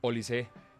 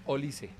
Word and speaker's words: Olise: La correcta pronunciación es Olise. – Ok, no Olise. – No es Olise, Olise, sino Olise.